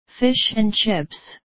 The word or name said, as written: fish and chips